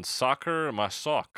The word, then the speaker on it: Sacher-Masoch: By Leopold von Sacher Masoch. I'm probably saying that wrong, but it's Sacher-Masoch. – Yeah, I had the page up. Here it is. Yeah. Yeah, that sounds fine.